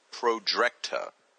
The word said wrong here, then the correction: Projectra: Tra. It's Projectra.